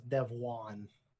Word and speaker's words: Devuan: They're spelled D-E-V-U-A-N. It's Devuan.